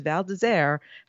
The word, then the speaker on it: Val d'Isère: I know how to pronounce that because Val d'Isère has been a race on the World Cup for as long as I can remember.